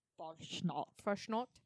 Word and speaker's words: Fastnacht: Fastnacht? Tell me, German, am I saying that right? – Fastnacht.